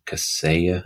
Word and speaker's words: Kaseya: So immediate examples are SolarWinds, Kaseya, I think is how you pronounce it.